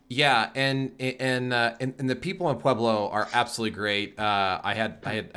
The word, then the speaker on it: Pueblo: It is Pueblo. I thought you people, you know, so I said you people, pronounced it Piebla. Some weird, because Coloradans have this weird way of pronouncing a lot of their cities. – I could be wrong, but I've always referred to it as Pueblo.